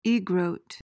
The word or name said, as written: egrote